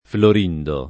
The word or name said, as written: Florindo